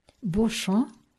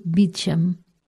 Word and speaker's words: Beauchamp: Ele de facto havia pronunciado o nome corretamente em francês, embora eu o tivesse dito na pronúncia inglesa comum de Beecham.